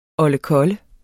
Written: oldekolle

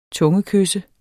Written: tungekysse

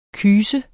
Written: kyst

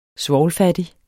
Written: svovlfattig

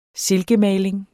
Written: silkemaling

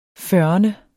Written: fyrrende

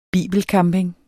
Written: bibelcamping

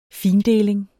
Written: findeling

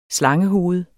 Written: slangehoved

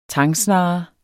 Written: tangsnarre

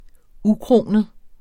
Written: ukronet

